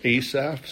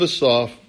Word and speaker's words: Asaph: It says a Psalm of Asaph, or Asaph. Some people pronounce it that way. It's okay, however you want to pronounce it. A Psalm of Asaph, verse 1 says, God stands in the congregation of the mighty.